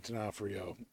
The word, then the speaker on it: D'Onofrio: You said it wrong. – How you pronounce it? – Vincent D'Onofrio. – Vincent D'Onofrio is Kingpin.